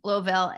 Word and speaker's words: Louisville: And you are from Louisville, Kentucky. Did I say that right? – You said it. There's so many ways to say it. That'll work. – I remember I used to say it Louisville.